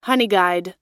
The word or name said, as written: honeyguide